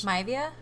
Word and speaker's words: Maivia: His mother, Ada Johnson, is Samoan, and both of her parents, High Chief Peter Mieva. I always mispronounce this. – Maivia? – Maivia.